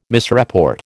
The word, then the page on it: misreport: American English